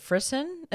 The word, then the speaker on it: frisson: Okay, now I'm seeing another Google search that says it's pronounced frisson. – Frisson? It's probably, so, yeah, butchering it. – Yeah, it makes a French, like a romance language, so very heavily used in music. So music, not fission, not frisson, frisson.